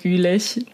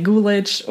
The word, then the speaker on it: Gülich: well, since we're all German, I feel like we can say Gülich, but I've been getting really used to the way Americans pronounce it. And um, I know they're trying really hard and they always ask me to help them out. So either way works for me if you say Gulich or Gülich, it works.